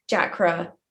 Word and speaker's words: Jakra: Like, of course, Jakra, you don't pronounce the H, but then, you know, we don't tell you guys that.